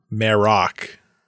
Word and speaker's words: Marrok: Merrock. – Spelled the same? – Spelled differently. Okay. Galen Merrick is M-A-R-E-K. Merrock is M-A-R-R-O-K. But they sound similar, very similar.